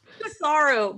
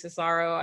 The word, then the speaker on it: Cesaro: I mean, Cicero. What, how do you say his name? – Cesaro. – My apologies, Cesaro.